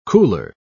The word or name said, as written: cooler